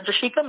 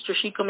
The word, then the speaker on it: Jershika: Um, so my social media Facebook page is J- Jershika Maple. Um, I have a music page. It's Jershika Maple Music. Um, and then my name is spelled J-E-R-S-H-I-K-A. Last name Maple, M-A-P-O-E.